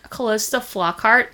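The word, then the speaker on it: Calista Flockhart: Okay, well, I think Callista Flockhart is sort of blonde, and... – Am I even saying the name right? – Yes.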